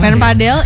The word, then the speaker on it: padel: Sebenarnya yang benar itu bacanya pedal, padel atau pahdel atau padel gitu. Yang benar sih padel. – Padel. Di British kan, Mas. – Padel aja padel. – Iya, sebenarnya kalau misalkan sepengetahuan gue ya, sebenarnya itu disebutnya itu padel bukan. Iya. Ya udah padel tuh ya padel bukan pedal gitu.